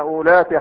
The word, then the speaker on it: أولات: ها أولاتي وإن كنا أولات حمل، أولات حمل أولات حمل، ها الواو موجودة ولا تنطق.